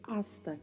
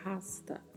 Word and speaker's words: hasta: Na pronúncia, a letra H, a letra H não tem som muito cuidado para não usar na pronúncia como se houvesse um R e falar rasta.